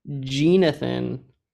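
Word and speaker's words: Jeenathan: I'm not saying that wrong. J-E-E-N, Jenathan Williams at small forward.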